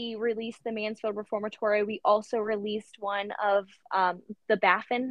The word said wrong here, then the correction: Banff: Is that how you pronounce it? – Banff. – Banff. There you go.